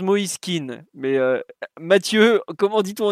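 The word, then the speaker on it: Moise Kean: je crois qu'on prononce, on prononce mais en Italie, je ne sais pas pourquoi ils prononcent Moiseken.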